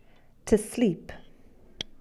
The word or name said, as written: sleep